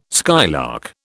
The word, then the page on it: skylark: British English